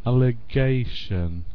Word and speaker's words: allegation: You know, the stress is before the last syllable when the word ends with T-I-O-N. Da-da-da-da. Allegation.